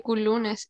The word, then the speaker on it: Couloonas: Yo, me, yo me acostumbré a decirle culunas y como que siempre lo leo así. – Es que en verdad esa debería ser la pronunciación oficial, pero...